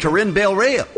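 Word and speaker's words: Corinne Bailey Rae: Corinne Bailey-Ray.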